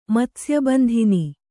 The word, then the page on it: ಮತ್ಸ್ಯಬಂಧಿನಿ: kannada